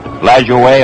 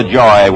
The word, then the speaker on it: Lajoie: Pronounce it Lajoie or LaJoy, whichever you like, still alive.